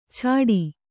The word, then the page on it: ಛಾಟಿ: chāṭi